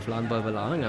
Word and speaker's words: Landbouwbelang: We are in the food bank area of Landbouwbelang, am I saying that correctly? – Yeah.